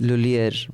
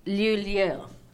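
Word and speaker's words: Lhuillier: Lulier, Monique. – Lulier.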